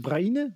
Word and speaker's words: Braine: En Royal Castors, is dat hoe spreek je, is dat Braine? Brain? – Brain, Brain, Brain. Ja.